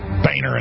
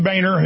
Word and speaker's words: Boehner: Well, that socialist, kleptocratic sellout to the New World Order, John Boehner, that's how he pronounced his name. We're not going to go under his mind control and call him Boehner anymore because he tells us to, has gone off on the right, as he calls it.